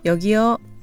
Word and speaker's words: 여기요: It's spelled 여기요, but colloquially people say 여기요, 여 rather than 요 at the end. So 여기요 rather than 여기요 to sound more natural. Repeat after me. 여기요.